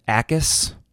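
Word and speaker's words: Jacas: Gabe Akis, it's a silent J, got in there.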